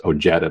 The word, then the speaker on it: Ojeda: That's why his name is Ojeda, but he says it with the hard J.